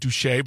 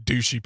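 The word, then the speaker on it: Douchy: He was also buried in the family home in sorry, but it just looks, I know this is a very American, I know it's probably pronounced Douche, but it looks like Douchey Paris, but it's south of Paris.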